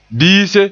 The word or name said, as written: biise